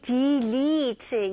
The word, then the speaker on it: delete: 발음은 d, i 발음. 첫 번째 음절은 d, i로 생각하세요. 그래서 delete보다는 d, 그 다음에 마지막은 e 발음이에요. leet, delete, delete. 네, 그래서 스펠링은 다 e가 들어있지만 발음은 달라요.